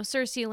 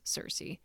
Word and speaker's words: Circe: But yeah, I believe the correct pronunciation is Circe.